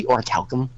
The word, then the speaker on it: orichalcum: Orichalcum? Really? – Why? – That's just a random-ass metal. I'm also really proud I read that right. Orichalcum. – It's actually pronounced orichalcum.